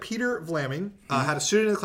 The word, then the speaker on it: Vlaming: Virginia Peter Flaming? – Vlaming. – Vlaming. – Vlaming. Uh-huh. Yes.